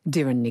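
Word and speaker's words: Doireann: which is how to spell Dorina Griffith, because it is an Irish name. Dorin is spelt Dorianne.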